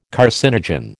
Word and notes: carcinogen: American English

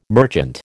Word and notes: merchant: American English

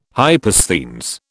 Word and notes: hypersthenes: British English

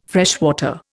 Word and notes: freshwater: Indian

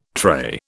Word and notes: tray: British English

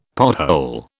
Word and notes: pothole: British English

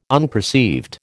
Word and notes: unperceived: American English

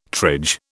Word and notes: triage: British English

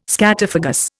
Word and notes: scatophagous: Indian

